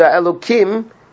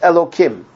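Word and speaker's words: Elokim (אלוקים): And if you notice, the Elokim is written Yudke Vavke, but it's pronounced Elokim.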